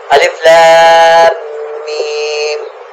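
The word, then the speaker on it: الم: ان حروف کو الگ الگ کر کے پڑھائیں, ہجے نہ کرائیں. الف لام میم,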